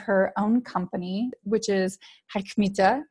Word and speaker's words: HMÉTÉ: Yeah, you say HMÉTÉ, so it's like it's a French pronunciation.